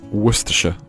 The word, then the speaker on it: Worcestershire: Worcestershire. – 好，最后一个单词，他就是希望我来给他读一读。最后这个单词，这个单词其实我都没听清楚是啥。So what was that? – Worcestershire.